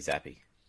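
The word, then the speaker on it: Zappe: How many firsts is Bailey, is it Zapp or Zappy worth? – What? You don't even know how to say his last name. – Mate, I don't care about Patriots. – He's the new Tom Brady. It's Bailey Zappy.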